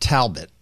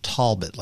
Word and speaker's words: Talbot: It's not Talbot because that's what you would say if you were up north. It's Talbot, like there's an O, but it's spelled with an A go go go figure.